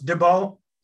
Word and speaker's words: Dubeau: Dubeau.